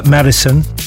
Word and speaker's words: MDSN: That's pronounced Madison. It's capital M, capital D, capital S, capital N, and a track entitled Jealous.